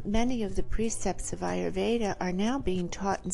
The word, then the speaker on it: Ayurveda: And I was doing my research, and what exactly, for the people who don't know, Ayurveda, did I pronounce that correctly? – Ayurveda, you did.